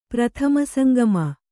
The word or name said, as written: ಪ್ರಥಮಸಂಗಮ